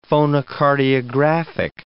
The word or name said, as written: phonocardiographic